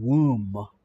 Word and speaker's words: womb: And so the old German, from which the English word womb is borrowed from German, the one with the womb, W-O-M-B, which is really, it's hard to pronounce, isn't it? Womb.